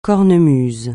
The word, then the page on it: cornemuse: francais